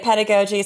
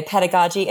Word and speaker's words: pedagogy: Some people say pedagogy. Some people say pedagogy.